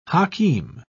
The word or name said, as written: hakeem